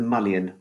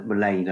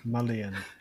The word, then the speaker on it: Mullion: It's the Mullion rather than the Mullein, I think, which is a sort of very exotic pronunciation. – Oh, really? Oh. – Mullion.